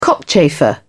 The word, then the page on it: cockchafer: British English